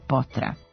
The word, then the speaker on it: Potra: Evo baš jutros novinar čita sportske vesti i pominje tenisera Juana Martina del Potra. Akcenat je tu na drugom, a ne na prvom slogu.